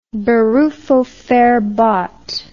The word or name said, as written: berufsverbot